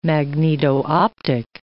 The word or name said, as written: magneto-optic